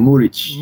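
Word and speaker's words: Muriqi: And they would get a little bit more in the 42nd minute with Vedat Muriki. Is it Muriki? Is that how it pronounced? – It's Muric. Muric. That's how the commentator says it.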